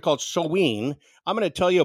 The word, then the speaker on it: Samhain: They celebrated this Samhain, and it's kind of interesting, because even though you say it Samhain, the way you spell it is Samhain, but it's pronounced Samhain.